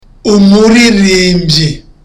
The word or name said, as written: umuririmbyi